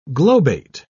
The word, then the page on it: globate: British English